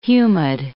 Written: humid

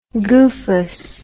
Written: goofus

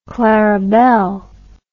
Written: clarabelle

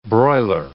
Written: broiler

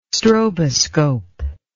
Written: stroboscope

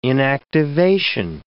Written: inactivation

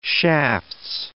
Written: shafts

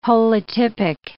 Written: holotypic

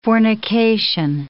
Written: fornication

